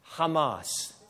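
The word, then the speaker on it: Hamas (חמס): The word translated violence here in the Hebrew is the Hebrew word that is pronounced Hamas.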